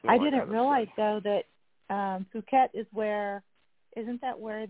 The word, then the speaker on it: Phuket: Yes, it's just because they're going, I'm sending them from Bangkok to the one that killed me, and I don't know how you pronounce it, but it sounds like Phuket. It's Phuket. Phuket, that's where I was at.